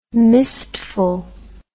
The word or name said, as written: mistful